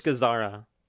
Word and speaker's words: Gazzara: Ben Gazzia. And Seymour Castle. Kissel. This is John Cassavetes. – I think it's Gazzara.